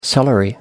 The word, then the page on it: celery: American English